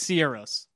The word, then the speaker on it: Sigur Rós: That's not how you say it, but okay. – How do you say it? – It's Sierros.